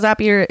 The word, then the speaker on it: Zapier: It's pronounced like happier. And particularly, the thing that you need to know about Zapier is that it's one of the easiest ways that you can automate your work.